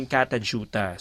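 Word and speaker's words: Kata Tjuta: Ito sa Northern Territory yun pala yung pronounce yung Katajuta.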